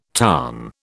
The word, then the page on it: tarn: British English